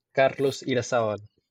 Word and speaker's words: Carlos Irazabal: And I'm Carlos Irazabal.